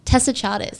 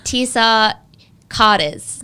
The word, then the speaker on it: Tessa Charters: Tessa Charters. She mispronounced my name. – How did she pronounce it? – I don't even know. It was like Tisa Carters.